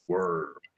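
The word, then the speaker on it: Wurm: It's spelled with a U, so, you know, it's Worm.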